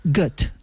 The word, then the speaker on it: Goethe: Y según he visto, se pronunciaría Goethe. – Mira, bueno, como Gota...